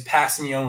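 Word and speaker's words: Kpassagnon: And yes, for all of you linguistic listeners out there, that is Passignon with a silent K at the beginning of the world.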